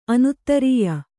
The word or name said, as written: ಅನೌದಾರ್ಯ